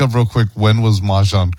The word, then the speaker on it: Mahjong: Yeah, it's Mahjong.